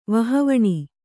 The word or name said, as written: ವಹವಣಿ